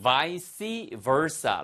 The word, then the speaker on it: vice versa: 그러면 발음은 vice versa. 라틴어에서 나온 말이 어렵죠? verse가 돌단 뜻이에요. 그러니까, 거꾸로도 그렇다라는 얘기죠. 그래서, vice versa 아니면 vice versa. 두 가지 발음 가능합니다.